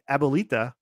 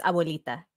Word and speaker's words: Abuelita: That's the Abuelita. Did I say that correctly? – You're close. I'll give you B+ for that. No, it's Abuelita.